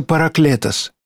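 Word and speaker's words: параклетос (παράκλητος): Слово «утешитель» по-гречески произносится «параклетос».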